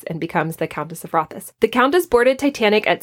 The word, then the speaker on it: Rothes: Uh, it's not Countess of Roths, it's Rothes think I got that right.